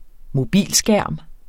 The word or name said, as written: mobilskærm